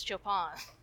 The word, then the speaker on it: Chopin: I mean, I call him Chopin all the time, but it is Chopin. – Like, yeah, take Chopin, but make all this stuff not violins, guitars.